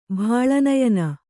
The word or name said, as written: ಬಾಳನಯನ